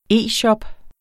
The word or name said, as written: e-shop